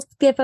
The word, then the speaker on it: SCIF: Listen, we'll skiff about over donuts. – It. Exactly. So I looked it up and it's spelled SCIF, and it stands for Sensitive Compartmented Information Facility.